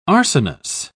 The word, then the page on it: arsenous: British English